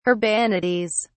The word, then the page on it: urbanities: British English